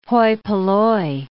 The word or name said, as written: hoi polloi